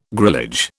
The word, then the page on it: grillage: British English